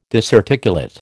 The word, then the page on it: disarticulate: American English